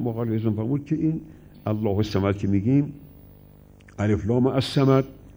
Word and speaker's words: الله الصمد: موقعی گفتن الله الصمد، های الله می خوره به صاد صمد، اون الف لام تلفظ نمیشه.